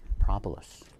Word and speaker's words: propolis: And I encourage you to Google anything you can to find out more about propolis, or as Lori Yarborough says, he pronounces it the right way, propolis.